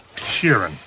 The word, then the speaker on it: Sheeran: Sheeran. I saying that right? – Yeah, you said right.